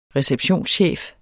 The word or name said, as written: receptionschef